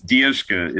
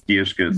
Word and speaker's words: Dierschke: It's Dierschke. It's Dierschke. – So Dierschke. – Yeah. Yes. It's a German great name, so.